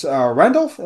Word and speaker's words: Randolph: Also, I do want to say for Miss Randolph, am I pronouncing that correctly? – Yes.